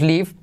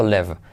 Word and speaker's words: live: Se escribe con I, todos decimos live, pero no se dice live. Live. left. Como elefante. Elefante. No dices elefante. No se dice elefante, por lo tanto lev, lev, lev. Para hacer el inglés, la i como una E. Se escribe con I, se dice con E, lev.